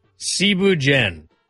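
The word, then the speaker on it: thieboudienne: First off, Cebu Jen. It's spelled like Thibu Dien.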